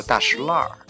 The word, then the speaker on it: 大栅栏: 去过北京的人都知道大石烂儿，哎，你不能按字面读音，弄得我以为这是一可能是读文章读出来的呢，挺逗的。